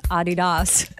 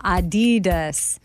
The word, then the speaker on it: Adidas: It's supposed to be Adidas, with a slight emphasis on the first syllable, not Adidas.